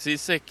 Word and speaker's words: Sisek: Farsa, du kan väl börja med att rätta mitt uttala efternamnet där ifall det inte var korrekt. – Nej, men det var helt korrekt. Det var helt korrekt. Sisek, Christian Sisek som han heter.